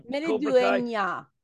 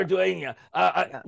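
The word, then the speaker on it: Maridueña: Zolo Mereduena, if that's how you pronounce his name, from Zolo from Mereduena. The last syllable is nya. I asked my friend in Mexico. – Mereduena.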